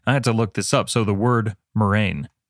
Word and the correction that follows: murrain: Oh, Murren. That's the, I don't know if you can hear the, uh, the pronunciation lady from Google. Murren. Murren. A murren, M U R R A I N, is an infectious disease, especially babesiosis, affecting cattle or other animals, or archaic, meaning a plague, epidemic, or crop blight.